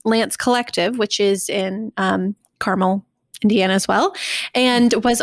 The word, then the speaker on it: Carmel: Lance Design, a full-service, family-owned interior design firm based out of, is it Carmel, Indiana? – It's Carmel. – Carmel, Indiana. There you go. I knew I'd mispronounced something.